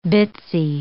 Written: bitsy

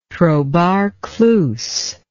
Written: trobar clus

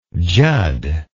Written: judd

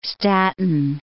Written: Staten Island

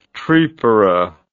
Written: tripara

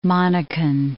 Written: Monacan

